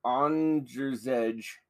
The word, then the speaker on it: Andrzej: How do you say that name? Andre that ends with a J. Is it Andre still? – Andrzej Grabowski. I got Grabowski.